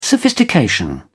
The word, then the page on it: sophistication: British English